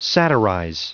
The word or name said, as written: satirize